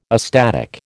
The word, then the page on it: astatic: American English